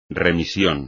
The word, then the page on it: remisión: espagnol